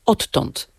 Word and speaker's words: odtąd: Pisownia inaczej, wymowa inaczej, ale w tej formie, którą pan przywołał, wymawiamy podwójne t odtąd, mimo że w zapisie ortograficznym mamy d i t.